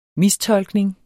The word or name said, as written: mistolkning